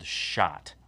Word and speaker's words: Schott: there's a company called Schott, which is spelled S-C-H-O-T-T. Schott. – Yeah. – Or is it S-H-O-T-T? – No, it's S-C-H-O-T-T, yeah, but whatever.